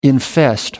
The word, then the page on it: infest: American English